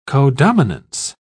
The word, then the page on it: codominance: British English